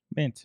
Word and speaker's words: MNT: I don't even know how you pronounce MNT. – Mint.